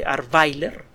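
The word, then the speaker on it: Ahrweiler: El, pueblo, más bien el distrito de Arweiler, se escribe A-H-R y luego W-E-I-L-E-R.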